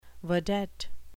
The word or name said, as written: vedette